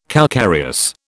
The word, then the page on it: calcareous: British English